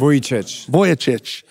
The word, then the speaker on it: Vujicic: And I'm here with a proponent of that, Nick Vujicic. I said that right? – Vujicic.